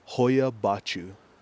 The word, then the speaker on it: Hoia-Baciu: Hoya Bachu. – Oh, that sounds like an evil spell. – Yeah, Hoya Bachu is when you look at a like world's creepiest forest.